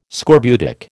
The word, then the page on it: scorbutic: American English